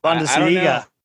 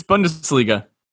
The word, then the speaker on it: Bundesliga: Bundesliga.